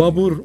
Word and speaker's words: Babur: Babur okunur. Batırlar Baber okur.